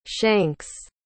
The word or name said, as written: shanks